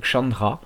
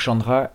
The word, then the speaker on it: Khandra: Le groupe a un nom qui s'écrit Khandra, K-H-A-N-D-R-A. Et il semblerait que cela se prononce Chandra.